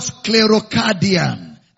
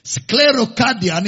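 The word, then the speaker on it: sklerokardian: The word hardness of your heart is the word sclerocardian. I can spell it for you. Sclerocardian in the Greek. S-K-L-E-R-O. Sclero. Cardian. K-A-R-D-I-A-N. Cardian. Cardia is your heart.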